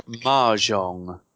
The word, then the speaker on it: Mahjong: Between that and Mojang, that's pretty much it. – I think you'll find it's pronounced Mahjong.